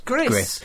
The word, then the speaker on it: Gris: Which is definitely pronounced Gris. G-R-I-S.